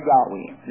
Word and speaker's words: Yahweh: and there's much discussion over the pronunciation, Jehovah or Yahweh, and the Jews were so afraid to pronounce it.